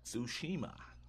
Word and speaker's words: Tsushima: I'm horrible at pronouncing these type of names, but Tsushima. – Tsushima.